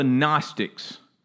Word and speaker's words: Gnostic: What was happening, there was a group called the Gnostics, and you spell this G-N-O-S-T-I-C, all right? So you've learned something today, you now know how to spell Gnostic, all right? G-N-O-S-T-I-C. The G is silent.